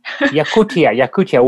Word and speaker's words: Yakutia: I mean, there were a few deposits of Russian diamonds in kimberlite, but they hadn't, of course, discovered the diamonds in Yakutia, is that how you pronounce it? – Yakutia, way in the Far East.